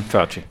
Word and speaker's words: Fauci: Fauci, right? – Mm-hmm. Fauci.